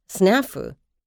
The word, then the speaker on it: snafu: Well, while English tends to favor initial stress, there's no reason for this to be always true. Besides, snafu with initial stress, is the alternative pronunciation.